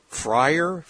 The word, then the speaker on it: Freyr: Freer. F-R-E-Y-R. It's missing a syllable or a vowel in there somewhere.